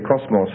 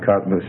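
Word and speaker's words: cosmos (κόσμος): Okay, real good, except for cosmos, say cosmos rather than cosmos. See, you're getting the English English word mixed in with the other. So the omicron, we're always pronouncing an O sound. So cosmos, cosmos, okay?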